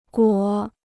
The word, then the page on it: 裹: guǒ